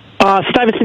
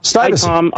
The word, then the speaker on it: Stuyvesant: Am I saying it right? Stuyvesant? – Stuyvesant, New York. – Stuyvesant.